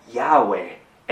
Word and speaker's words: Yahweh: Y yo soy es YHWH en hebreo, que comúnmente se pronuncia como Yahweh en inglés y Jehová en español.